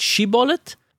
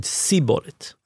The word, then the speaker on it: shibboleth: Because one tribe said it shibboleth, and one tribe said Siboret.